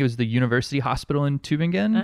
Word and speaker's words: Tübingen: You traveled to Germany and went to in Tübingen. You went to That's good pronunciation. Yeah. – Is it right? – Yeah. Tübingen. – Yeah, I thought it was right.